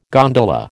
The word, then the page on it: gondola: American English